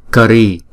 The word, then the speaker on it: kari: Kari, kari. Không? Chữ A này không có dấu gạch ngang trên đầu cho nên chúng ta đọc là ơ, kari.